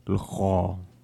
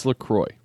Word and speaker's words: LaCroix: L'chol. – No, say it. – L'chol? – No, say it right. – L'cha. – No, say it right. – It's LaCroix.